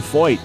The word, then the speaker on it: Feucht: Speaking with Sean Foyt, want to find out more? His name is spelled F-E-U-C-H-T.